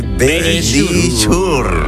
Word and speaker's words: Verisure: Nuestros amigos de Verisure.